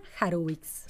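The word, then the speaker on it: Harwicz: Se llama Ariana Harwitz. – Se escribe Har, H -A Con H, H-A-R-W-I-C-Z.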